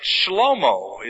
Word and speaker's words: Shlomo: By the way, that would be the Hebrew pronunciation for Solomon. We say Solomon, that's inaccurate. Shlomo is the guy who's on the throne. That's Hebrew. So he knows that Shlomo's on the throne.